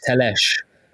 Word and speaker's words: Telles: So I said, oh, how do you pronounce it? And he said, Alex Telles. So I suppose the Portuguese have a slightly different pronunciation than the Brazilians, but you know, we need to go with the Brazilians, don't we?